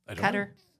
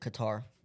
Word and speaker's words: Qatar: Is it Qatar or is it Qatar? – It's Qatar. – Oh. – Or Qatar, depending upon who you ask. And it's actually yeah. pronounced like Qatar. – Right. Oh. – Fair. Okay. There's that. – I don't think I'm that far off. – Everything is... Qatar or Qatar are both acceptable, is my understanding.